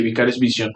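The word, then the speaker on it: Vicarious Visions: O sea, eh, Overwatch, Diablo, entonces posiblemente ya no veamos un nuevo juego de Tony Hawk desarrollado por Vicarios Vicios. – lo dije bien? Vision. – Vision. Eh, sí, Vicarios Vision.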